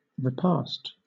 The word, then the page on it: repast: British English